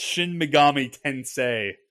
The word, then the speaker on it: Shin Megami Tensei: You're close. It's Shin Megami Tensei.